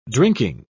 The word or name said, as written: drinking